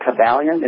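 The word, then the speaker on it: Kybalion: And the Kabbalon, how you had came, is it, am I saying that right, the Kabbalon? – Actually, the Kabbalion.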